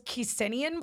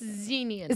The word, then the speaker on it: Xenian: But because of the way it's pronounced in Japanese, they thought it was Kisinian Blossom. – Okay. – So that's what they called it. – So it's Xenian.